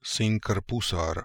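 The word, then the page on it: Sinkrpoosarams: Russian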